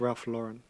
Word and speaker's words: Ralph Lauren: That's actually a mispronunciation. It's Ralph Lauren.